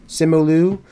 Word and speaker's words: Simu Liu: Simu Liu? – Simu Liu.